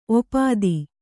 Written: ಒಪ್ಪೊತ್ತು

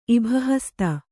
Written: ಇಭಹಸ್ತ

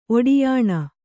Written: ಒಡ್ಯಾಣ

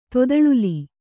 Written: ತೊದಳಿಲಿ

